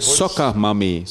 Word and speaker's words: Soccer Mummy: Also nicht äh, wie Saugen Soccer, sondern wie Fußball. Also Soccer. Ah, ja. – ja? Soccer Mami, so spricht man das aus.